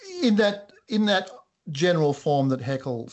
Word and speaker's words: Haeckel: All right, then let's go to, is it pronounced Haeckel? – Haeckel, yeah.